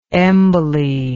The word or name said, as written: emboly